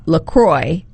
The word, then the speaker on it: LaCroix: And it is officially pronounced LaCroix. – Yes. – So rhymes with enjoy, LaCroix, not LaCroix or LaCroix.